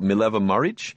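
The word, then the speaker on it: Mileva Marić: We hear more of it, that his first wife, I don't know if I have the pronunciation correct, but Mileva Maric? – That's correct.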